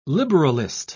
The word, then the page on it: liberalist: British English